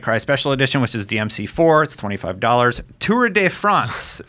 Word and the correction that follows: Tour de France: Should 100% as a French-Canadian be pronouncing that better. – Tour de France. – Yeah. – France. – De France. – There we go.